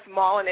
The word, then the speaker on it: Mollon: And, yes, Mollon is Caribbean. – Mollon, I was hoping I was saying it right. – Oh, yeah. Yeah, I hear Mollon, I hear it all, so I don't even bother to correct people anymore.